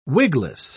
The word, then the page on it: wigless: British English